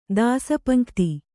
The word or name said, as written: ದಾಸಪಂಕ್ತಿ